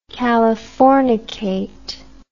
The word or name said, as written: californicate